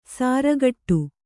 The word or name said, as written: ಸಾರಗೆಡು